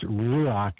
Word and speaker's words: ruach: some people call it rock. It's ruach. It's the same word as prana. It means prana, basically, in Hebrew.